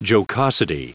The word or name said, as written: jocosity